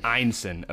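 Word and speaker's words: Ineson: Einson.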